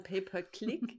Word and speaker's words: Pay-per-Click: Nicht Pay-per-Click, sondern Google Ads Marketing oder nicht Pay-per-Clip, sondern Pay-per-Click.